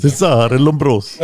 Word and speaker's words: Cesare Lombroso: Och han hette, nu är han italienare och jag är inte jättebra på att uttala namn, men Cesare Lombroso. Cesare Lombroso eller något sånt. – Cesare Lombroso.